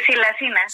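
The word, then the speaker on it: xilacina: No sé si es silacina o silacina. ¿Cómo se pronuncia, doctora? – Sí, silacina. – Silacina, correcto.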